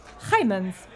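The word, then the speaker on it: Heijmans: Thanks to all those people who suggested the Dutch pronunciation of Heimans.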